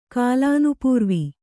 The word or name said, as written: ಕಾಲಾನುಪೂರ್ವಿ